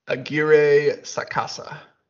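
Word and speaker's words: Aguirre-Sacasa: Roberto, Roberto Aguirre Sacasa.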